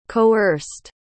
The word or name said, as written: coerced